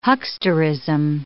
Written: hucksterism

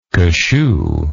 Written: cashoo

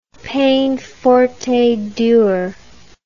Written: peine forte et dure

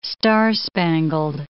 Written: star-spangled

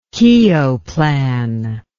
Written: Keogh plan

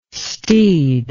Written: steed

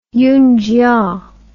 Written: yungchia

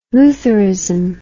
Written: lutherism

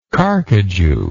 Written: carcajou

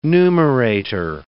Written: numerator

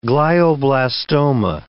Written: glioblastoma

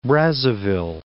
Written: Brazzaville

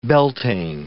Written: Beltane

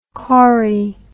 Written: chori

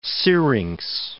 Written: syrinx

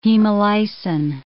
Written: hemolysin

